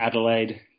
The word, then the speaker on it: Adelaide: Adelaide. Pretty simple. – Adelaide.